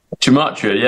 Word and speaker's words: Gematria: Gematria, right? Am I saying it right? – Gematria, yeah.